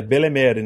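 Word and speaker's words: Bellemere: Mas que é uma mãezona que é a personagem Beremere, que é Belemere, na verdade, Belemere se escreve.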